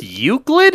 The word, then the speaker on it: Euclid: The three main classes are safe, lucid, you said, and Euclid. – I think it's pronounced Euclid? – Euclid? Yeah. – Yeah, it's Euclid.